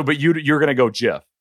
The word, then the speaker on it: GIF: GIF. The guys who started it said it's GIF.